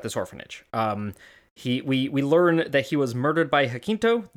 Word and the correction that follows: Jacinto: It's a soft C, Jacinto. – Ah, Jacinto, thank you.